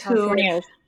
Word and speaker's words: Californios: Except that what about the women who are Californios? How did you say that in the book? Those Californios.